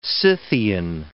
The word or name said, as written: Scythian